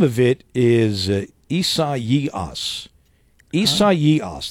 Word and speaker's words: Isaias: It is spelled Isaiah, but it is pronounced Isaias.